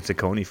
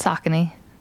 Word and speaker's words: Saucony: And I was today years old when I found out how to pronounce Saucony. Wow. I kept on saying Saucony for my whole life. So I think that's right. – I've been told Saucony.